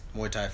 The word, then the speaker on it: Muay Thai: We're going to talk about you a little bit as the president of the United States Muay Thai Federation.